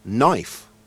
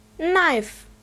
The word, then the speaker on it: knife: Number five is knife. Aber da steht doch noch ein K davor. Wieso spricht man das denn nicht mit? – Bei manchen Wörtern wird der erste Buchstabe im Englischen einfach weggelassen.